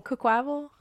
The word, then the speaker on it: Quaquaval: It's Quaquaval? – Quaquaval? – Like Quack Quack? – Quaquaval.